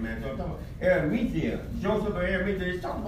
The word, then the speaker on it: Arimathea: That's it. Arimathea. That's how you pronounce the name.